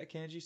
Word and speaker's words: Akanji: Is it Akanji? Is that how we're pronouncing it? – I think so. – Yeah, Akanji.